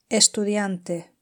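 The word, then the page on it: estudiante: Spanish (Spain)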